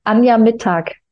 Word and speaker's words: Anja Mittag: Now I have to say my name, right? – Yeah, now you have to say your name. – Anja Mittag. – Anja Mittag. – Yeah, that's good.